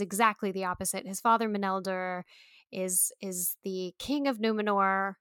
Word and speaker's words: Meneldil: It's Meneldil. – It is, in fact, Meneldil.